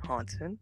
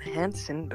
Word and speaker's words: Hansen: Yeah, it's spelled Hansen, but pronounced Hansen. Okay. It's not Henry. It's not Hayden, and it's not handsome. Although many people, you know, mistake it for that word.